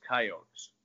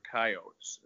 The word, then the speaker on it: Coyotes: By the way, the only pronunciation I'll criticize you on, and USD fans might as well, it's Coyotes. You can't call them Coyotes. They're Coyotes. And so that's I'm just giving you a bad time.